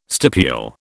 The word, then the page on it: stipule: British English